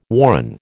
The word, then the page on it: warren: American English